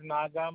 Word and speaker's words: Naga: My name is Naga Manachi.